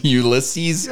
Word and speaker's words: Ulysses: Ulysses.